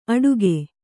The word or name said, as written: ಅಡುಗೆ